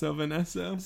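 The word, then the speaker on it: Sylvan Esso: It's actually pronounced Sylvian Esso.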